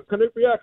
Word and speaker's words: Quinnipiac: We're proud of the Quinnipiac hockey.